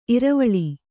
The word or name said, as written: ಇರ್ವಲ